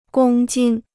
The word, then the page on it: 公斤: gōng jīn